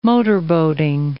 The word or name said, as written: motorboating